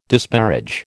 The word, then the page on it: disparage: American English